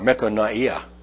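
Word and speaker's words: metanoia (μετάνοια): It's the Greek word metanoia, or metanoia, if you would like to pronounce it more properly.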